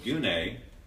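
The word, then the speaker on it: gune (γυνή): The Greek word that's used here is the word gune, G-U-N-E, pronounced gune.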